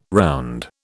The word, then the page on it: round: British English